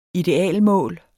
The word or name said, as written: ideal